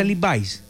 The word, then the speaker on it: Levi's: Es que así se pronuncia Levi's. – Levi's. – Sí.